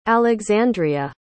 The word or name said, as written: alexandrea